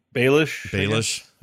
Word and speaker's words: Baelish: Peter Baelish or whatever you say his name. – Baelish, is that it? Yeah.